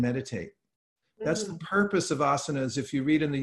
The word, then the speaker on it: asanas: And the reason is, actually, historically, asanas, the people call asans nowadays, it's actually pronounced asanas, but these are a place where you sit down to be more focused.